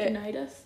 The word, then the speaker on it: Eugenides: I just googled how to say Eugenides.